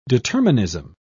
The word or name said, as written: determinism